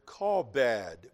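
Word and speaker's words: kabod (כבוד): If we were to pronounce it in Hebrew, it would be something close to the word kabod. We would say it that way.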